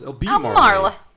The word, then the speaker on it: Albemarle: No, the company's name is called Albemarle. It's Albemarle.